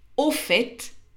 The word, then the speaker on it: au fait: Par contre, au fait, et on prononce aussi le T, veut dire soit dit en passant, à propos.